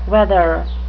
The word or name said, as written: wether